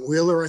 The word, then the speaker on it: Wehler: Okay, third case is adoption of the decision in the case of Brian M. Wheeler, I think it's pronounced, W-E-H-L-E-R, case number 2020-15.